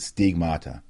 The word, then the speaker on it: stigmata: That's, you actually pronounce it stigmata in the Greek.